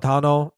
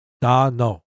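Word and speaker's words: Dano: Tanuk? – No. Da-no. – Da-no. – Yeah. – Da-no duck. Like Dino. – Da-no duck.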